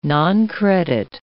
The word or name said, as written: noncredit